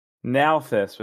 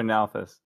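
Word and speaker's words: Nalthis: So there's also this coin, which I assume is from, gosh dang it, Endowment, Breath, Warbreaker, Malthus with an N, not M. Or no, it is Malthus. Is it Malthus or Nalthus? – An N. However all I'm right. pronouncing it, it's with an N. – N right. as in Nancy, not M N as in Mancy.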